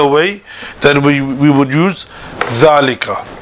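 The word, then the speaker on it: dhalika (ذلك): So dhalika is pronounced as if it were written with an alif. So pronunciation is like that, but written without the alif, but with a long fatha on top of dhal.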